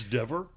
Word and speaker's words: Dever: It stars Caitlin Dever. I found out, it turns out I was mispronouncing her name on Breakfast All Day. – It's Dever? – It is Dever.